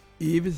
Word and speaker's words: Yves: Iv si scrive Y, Verona, Empoli, Savona. È un nome francese e le ultime quasi due lettere non vanno pronunciate.